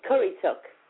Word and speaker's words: Currituck: Right, so she'll be walking approximately 250 miles from, now I'm going to say this wrong, Currituck. Does that sound right? – What is it? – Currituck, North Carolina.